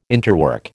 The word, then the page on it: interwork: American English